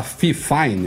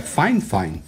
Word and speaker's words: FIFINE: Priscila Klopper fez um review do microfone eu ia falar FIFINE, mas eu vi ela perguntando pro pessoal da FIFINE como é que fala o nome da empresa, e eles falaram que é Fine Fine.